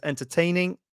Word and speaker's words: entertaining: And I just want to point out that you have to stress entertaining, right? Entertaining. The stress is on tain.